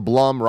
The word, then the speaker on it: Blum: And then, okay, so Gresnick writes in and says, for Blumhouse, the Blum rhymes with plum, and I need to remember that.